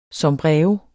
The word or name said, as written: sombrero